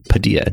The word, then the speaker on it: Padilla: My last name is Padilla, Derek Padilla, Like Tortilla. So you won't mispronounce it in the future.